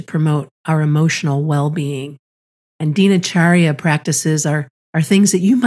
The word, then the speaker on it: Dinacharya: Yeah, Dinacharya, yeah.